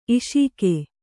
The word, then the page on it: ಈಷಿಕೆ: īṣike